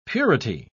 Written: purity

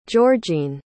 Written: georgine

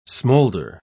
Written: smolder

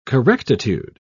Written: correctitude

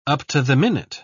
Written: up-to-the-minute

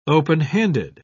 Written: openhanded

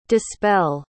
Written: dispell

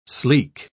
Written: sleek

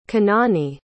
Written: kenani

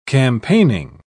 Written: campaigning

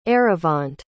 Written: eravant

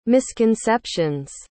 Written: misconception's

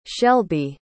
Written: shelbie

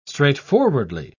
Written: straightforwardly

